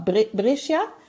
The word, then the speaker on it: Brescia: Brescia? – Genau.